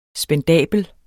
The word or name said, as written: spendabel